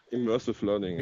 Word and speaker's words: Immersive Learning: ausgesprochen? Immerse Learning? Immersive Learning. – Immersive ja, genau.